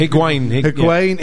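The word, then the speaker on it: Higuaín: How do you pronounce that? – Higuain.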